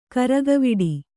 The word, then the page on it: ಗರಗುವಡೆ: garaguvaḍe; Kannada